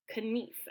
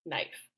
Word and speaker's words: knife: And in Middle English, that would have been correct. Knief, actually. And so when early modern English came about, this pronunciation was shifted. The spelling was kept and the pronunciation changed so that you say it the way that you say it now, which is knife. And so essentially we're cutting out that K and we're making that two E sound into like an I.